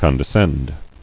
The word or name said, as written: Condescend